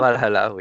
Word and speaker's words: Valhalla: Valhalla, c'est ça Valhalla, oui.